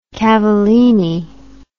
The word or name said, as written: Cavallini